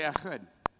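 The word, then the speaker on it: Echad (אחד): Somebody say Echad. Turn to your neighbor and say Echad. You're not saying it right unless they receive a little bit of spray. Say it, don't spray it.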